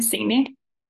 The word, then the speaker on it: Signy: I'm Signy, or Signy Karoline, but it's a bit long, so I just say Signy.